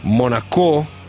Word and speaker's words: Monaco: Allora, leggo sui siti di un'asta tra Monaco, con l'accento sulla O, quindi quelli del Principato, e Paris Saint-Germain per Cristiano Ronaldo, super offerta dal Principato, 23 milioni di euro.